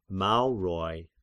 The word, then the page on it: Maurians: Mauroi